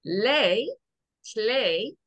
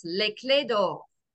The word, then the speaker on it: Les Clefs d'Or: It is Le Clé. The F is silent, as is the S. Le Clé d'Or. – So Le Clé d'Or.